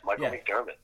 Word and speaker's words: Micheal McDermott: Yes, Micheal McDermott.